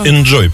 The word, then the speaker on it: inZOI: Enjoy, правильно говорить.